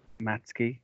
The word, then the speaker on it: Matzke: Matzke. I hope I said that right. – That's correct, yes.